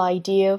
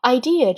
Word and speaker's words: idea: A single idea from the human mind can build cities. An idea can transform the world. An 和 idea 可以连读 An idea, 连读要注意一下哈 An idea, 连读音要快要轻 An idea. Idea 这个单词千万不要带卷舌，不要发成了 idea 哈，这里没有任何的卷舌音 An idea can transform the world.